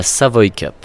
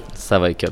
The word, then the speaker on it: Savoy Cup: Est-ce que tu peux m'aider déjà à le prononcer correctement Alors, on dit la Savoy Cup. Savoy Cup.